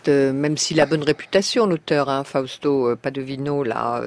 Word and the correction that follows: Paravidino: Paradivino, si vous voulez bien. Paravidino. – Paravidino. – Paravidino, si vous voulez enlever une syllabe.